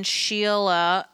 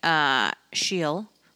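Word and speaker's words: Schiele: I went to the Museum of Fine Arts for the Klimt and Scheele opening. My whole life I've been saying Scheele, but apparently it's pronounced Scheele.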